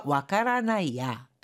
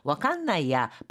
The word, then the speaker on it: わかんない: 그러니까 장식해 두건 했는데 이마 뭐 아르노까 와깐나이야 와깐나이 이거는 와까라나이에서 라가 응으로 바뀐 꼴로요. 이렇게 구어체에서는요 라행에 들어가는 음이 응 과 같이 바뀌는 경우도 많이 있습니다. 이거는 구어체입니다. 그래서 지금도 있는지는 모르겠어. 이마모 아르노가 와카라나이야 하는 걸 와칸나이야 발음하기 쉽게 하기 위해서 와카라나이가 와칸나이 이렇게 바뀐 겁니다.